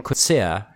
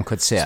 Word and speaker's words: Coetzee: So that's how you say his name. – I was just thinking the same thing. – He Coetzee. – I've always said Coetzee. – Yeah, you say Coetzee. Yeah. Yeah. Americans usually say Coetzee, but it's Coetzee.